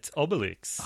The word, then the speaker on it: Obelix: Is it Obelisk? Or is it like Obelisk? – Obelisk? – It's Obelix, yes.